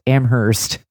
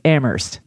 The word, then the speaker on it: Amherst: I mean, if he were that smart, he would know that it's not pronounced Amherst. We are recording this on my dad's birthday. He is a graduate. It's just Amherst. That's it.